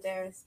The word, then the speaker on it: Aderes: Yeah, I say it Aderes, but it's not my real name, so you can say it however you like.